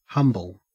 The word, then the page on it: humble: Australian English